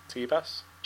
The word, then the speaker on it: Tebas: So, is that how it's pronounced? Tebas? – Sure.